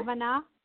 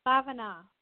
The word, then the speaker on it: Bavana: Bhavana. – What's your name? Say it again. – Bhavana. Bhavana. – Bhavana? – Yes. – Want to spell it? – B-A-V-A-N-A, Bhavana. – B-A-V-A-N-A, Bhavana.